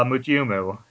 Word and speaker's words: Mutyumu: Uh, Mujumu. It's spelled uh, and it's a romanized form. Uh, M U T Y U M U. So like mute you moo. Huh. Like a, like a cow.